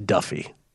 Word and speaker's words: Daffue: By the way, D-A-F-F-U-E somehow pronounced Duffy. Somehow. It's South African and it's pronounced Duffy.